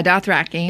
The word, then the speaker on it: Dothraki: Dothraki. How am I saying? Am I saying it right? – Yeah, Dothraki.